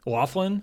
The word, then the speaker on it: Laughlin: Moving on to John Laughlin. – Laughlin? Thank you. – I'd say that because there is an actual city named Laughlin. And that looks like it's spelled that way.